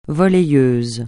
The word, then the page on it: volleyeuse: francais